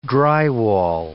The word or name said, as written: drywall